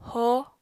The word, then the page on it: hoó: apatani